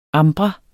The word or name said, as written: ambra